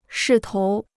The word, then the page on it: 势头: shì tóu